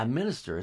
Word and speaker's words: Mxnxstxr: Yeah, Minister. It's spelled Minister. Very strange. – Yes, yes. – As we've covered, it's normal. MXNXSTXR. – Replaced all the other vowels with X's.